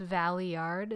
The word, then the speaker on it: Valeyard: It's pronounced Valeyard is something I learned after reading the name.